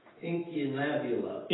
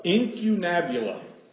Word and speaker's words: incunabula: Incunabula. – Incunabula. Incunabula.